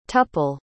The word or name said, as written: tuple